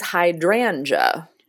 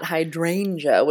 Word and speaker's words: hydrangea: Well, first of all, I found out when I was doing my research, I have been pronouncing it wrong for 20 years, probably. It is hydrangea. – Okay. – Not hydrangea, which is, you know, what I had always said.